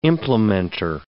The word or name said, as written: implementor